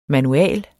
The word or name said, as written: Manualer